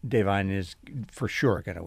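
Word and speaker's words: Da'Vine: Davine, is that how you say it? Yes, it's Davine.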